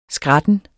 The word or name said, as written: skratten